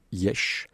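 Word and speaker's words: Iași: Donc nous sommes allés en Roumanie, dans une ville qui s'appelle Yesh, qui s'écrit Yassi,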